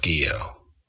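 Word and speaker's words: Guillot: And my father would hear that, and he would, you know, after a while, he said, you know, son, look, your name is pronounced Guillo, okay? And I said, well, people don't, whenever I say Guillo, they say, well, how do you spell it? And then you get into the long conversation, and they say, no, it's Gilliatt.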